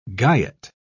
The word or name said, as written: guyot